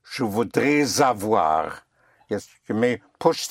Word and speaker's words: voudrais: Je voudrais is spelled, voudrais is spelled V-O-U-D-R-A-I-S. So we have an S here at the end, a silent S, which is followed by a vowel of have.